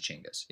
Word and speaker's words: Chinggis: The actual pronunciation of Genghis Khan, which is is actually Chinggis. Yeah, Chinggis Khan.